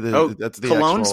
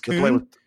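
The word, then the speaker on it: Köln: That's the way Cologne's pronounced Kuhn. – The way I was explained to me is that the Germanic, like, or the way it's spelled out, we pronounce is Kuhn. Of course, all those English speaking Americans, we say Cologne, but I was just being Kuhn.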